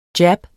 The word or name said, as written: jab